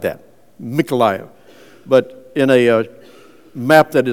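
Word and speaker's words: Mykolaiv (Миколаїв): In Ukrainian language, it comes out pronounced as with an M sound, Mykolaiv.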